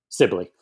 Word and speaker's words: Sibley: Sibley.